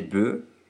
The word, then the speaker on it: bœufs: Enfin, je vous rappelle que certains mots finissant par le son « œuf » au singulier se prononcent « e » au pluriel. Par exemple, un bœuf, des bœufs, ou dans notre texte, un œuf, des œufs.